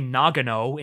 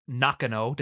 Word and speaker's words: Nakano: Uh, they say Nagano instead of Nakano, despite the fact that the sign literally says Nakano on screen.